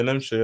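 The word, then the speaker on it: Nmecha: Is it Nemcha? – Yeah, Nemcha, yeah. – Okay, yeah, he did score a good goal.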